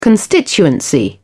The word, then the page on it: constituency: British English